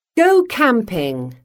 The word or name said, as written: go camping